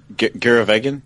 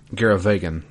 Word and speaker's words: Garavagan: And so we go to where he goes, how do you pronounce it? It's the G word. – The jungle? – No. The place where we're ending this episode. – Oh, Garavagan? – Garavagan. There you go.